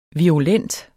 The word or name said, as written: virulent